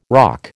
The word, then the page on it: roque: American English